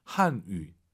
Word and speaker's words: 汉语: 한 위가 아니라 와유 발음은 입술을 동그랗게 말아서 쇼한유. 한유. 예, 위 하시면 안 돼요.